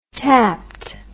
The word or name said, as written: tapped